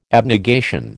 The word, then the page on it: abnegation: American English